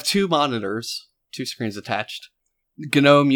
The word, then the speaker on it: GNOME: GNOME is the proper way of pronouncing it.